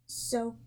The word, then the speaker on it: Xochimilco: So, you know that? Xochimilco? – Xochimilco? – That sounds good to me.